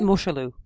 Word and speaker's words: Mosholu: the Mosholu, I'm totally mispronouncing that. Mosholu? – No, you're not. – Mosholu? – Mosholu.